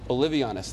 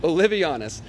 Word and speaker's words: Olivianus: Olivianus, thank you. Yep. Um, thank you. That's what I needed. Olivianus.